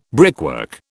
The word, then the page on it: brickwork: British English